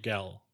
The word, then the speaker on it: Galveston: We've cruised out of Miami, Galveston, which I never pronounced right, in our 8 years together. Is it Galveston? – No, Gal. – Gal. – Galveston.